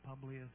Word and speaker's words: Publius: The name Publius is up for grabs if you want one. Actually, some people pronounce it Publius. You can pronounce it that way if you like. P-U-B-L-I-O-S.